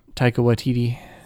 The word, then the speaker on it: Taika Waititi: It's got the dude who directed, I don't know how to pronounce his name. – Taika Waititi. – Is it? – Yeah. – Okay, yeah. – I think that's how it's pronounced.